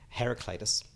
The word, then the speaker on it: Heraclitus: Heraclitus.